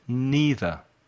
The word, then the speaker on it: neither: Now, we practice the difference between any, some, either, and neither. Let's look at either and neither first. There are two possible pronunciations. Listen. Either, either. Neither, neither. Repeat. Either, either. Neither, neither. Los dos son correctos.